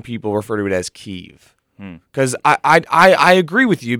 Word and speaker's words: Kyiv: I've been told that it is pronounced Kyiv if you are Ukrainian and Kiev if you are Russian. So there are different pronunciations based on the culture.